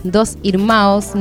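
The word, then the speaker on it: Dois Irmãos: Dos Irmaos, ¿no? Así se pronuncia en, en portugués.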